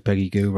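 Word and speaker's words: Peggy Gou: because Peggy Goo rhymes with shoe, and it happened at Glastonbury one year.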